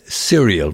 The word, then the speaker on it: serial: He is a Syrian killer. Serial, by the way, not Syrian.